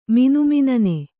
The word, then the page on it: ಮಿನುಮಿನನೆ: kannada